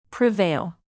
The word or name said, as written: prevail